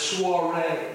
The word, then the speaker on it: soiree: It's soiree. It looks like sorry to me, you know.